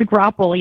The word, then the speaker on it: Graupel: Is it Graupel? How do you pronounce that? We were talking about that before. – It's a Graupel, yes. You had it right.